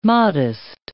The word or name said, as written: Mahdist